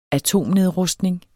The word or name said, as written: atomnedrustning